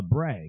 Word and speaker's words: labret: Many people call it a labret, because it's spelled L-A-B-R-E-T, but it's actually called labret.